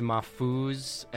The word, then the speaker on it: Mahfouz: It's properly, it's Mahfouz, M-A-H-F-O-U-Z is the Lebanese spelling and pronunciation.